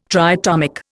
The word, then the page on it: triatomic: Indian